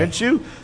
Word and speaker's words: Minshew: Minshew. Minshew Kyle Allen, Danny dropping dimes, and Brissett.